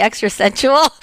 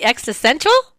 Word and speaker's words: existential: I always say the extra sensual, but it's the existential. Did I say that right? Existential, right.